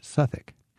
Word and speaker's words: Southwark: But in Southwark Cathedral, Southwick, but pronounced Southwick.